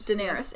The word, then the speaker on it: Daenerys: Am I saying that right? Daenerys. Daenerys. Yeah.